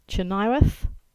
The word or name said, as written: Chynoweth